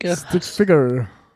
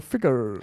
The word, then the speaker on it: Stick Figure: Alok und Stick Fiction. Ja, da haben wir es wieder, du kannst es besser aussprechen, als ich. – Stick Figure. – Ja. – Figure, also nicht Ficker, sondern Figure.